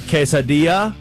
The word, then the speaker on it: quesadilla: Last night I had a quesadilla. Did I say that right? Did I get that? – Very good, Drake.